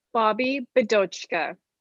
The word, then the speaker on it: Bobbi Bidochka: Bobbi Bidochka. It is phonetically correct. Just sound it out grade one style.